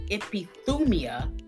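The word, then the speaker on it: epithumia (ἐπιθυμία): So John uses the Greek word epithumia. It's spelled E-P-I-T-H-U-M-I-A.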